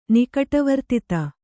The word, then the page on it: ನಿಕಟವರ್ತಿತ: Kannada